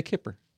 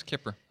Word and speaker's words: Kuiper: So you say Kipper. – Yeah, it is Kipper.